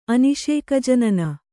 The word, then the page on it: ಅನಿಷೇಕಜನನ: aniṣēkajanana